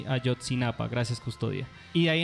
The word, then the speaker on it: Ayotzinapa: Pero empezaron a ganarse todo mi respeto cuando sucedió lo de la masacre de Azotzihualpa, perdón si no lo pronuncio bien. – Ayotzinapa. – ¿Cómo? Perdón. – Ayotzinapa. – Eso, de Ayotzinapa, perdón.